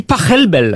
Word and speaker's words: Pachelbel: S'il est allemand, ça se dit Pachelbel.